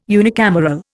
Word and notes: unicameral: Indian

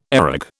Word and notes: areg: British English

